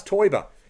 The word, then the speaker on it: Teuber: His second name rhymes with Neuber. – Klaus Teubahn? – Yes. – Close. – And Klaus Teuber.